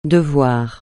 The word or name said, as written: devoir